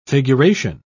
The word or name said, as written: figuration